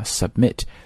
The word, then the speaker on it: submit: 其实我们在用电脑的时候经常说要提交一个什么东西，你都会看到这个键啊， submit，重音呢是落在第二个音节，落在 mit 上面， submit 啊。